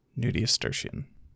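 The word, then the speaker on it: nudiustertian: Nudius Terstian. I don't think that N-U-D-I-U makes a nudia. – Nudius Terstian. – Nudius Terstian. Okay, so yeah. – Nudius Terstian. – Terstian. Not Ian. Terstian.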